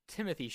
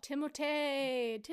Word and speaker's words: Timothée: Timothy Chalamet from Bones and All. – Timothée.